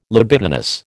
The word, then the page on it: libidinous: American English